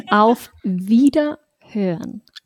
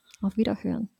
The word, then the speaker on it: Auf Wiederhören: Auf Wiederhören. Auf Wiederhören.